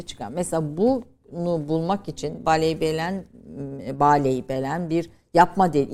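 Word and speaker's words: Bâleybelen: Mesela bu e, Baley Belen doğru te- telaffuz ediyor muyum hocam? böyle? – Baley Belen.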